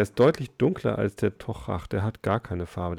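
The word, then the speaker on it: Toiteach: Horst Düding behauptet, er wird Tochach ausgesprochen. – Ja, ich habe auch schon mal Tokchak gehört. Mhm. Würde man es Englisch aussprechen, was draufsteht, würde man Toy Teach sagen ja, wahrscheinlich begegnet mal jeder Aussprache öfter mal.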